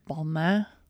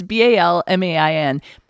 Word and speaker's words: Balmain: No, it's French. It's Balmain. But if you watched Legendary Season 1, you will pronounce it Balmain because that was the name of one of the teams.